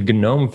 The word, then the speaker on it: GNOME: Previously, she served as executive director of the GNOME Foundation. Yes, there you do pronounce the G, it's not silent.